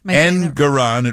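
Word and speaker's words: Engoron: I want to know, and I need to know, this judge, Ergonon? – Engeron, it rhymes with moron.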